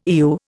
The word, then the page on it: Eu: Indian